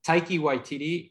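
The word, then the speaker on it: Taika Waititi: Taiki Waititi, whose name I'm probably butchering in every direction, he plays the bad guy and he's amazing in them. He's really fun. – That's actually the first name you've pronounced correctly on this podcast.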